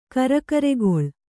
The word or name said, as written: ಕಿರಿಗರಕೆಲೆ